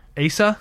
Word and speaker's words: Asa: How do you pronounce that kid's name? – I think Asa. Asa? – Yeah.